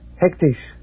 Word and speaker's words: hectisch: How do you say hectic in Dutch? – Hectisch. But hectic will also do.